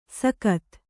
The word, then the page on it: ಸಕತ್: sakat